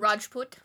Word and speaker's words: Rajput: Can you pronounce the Rajput? Rajput.